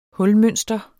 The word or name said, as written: hulmønster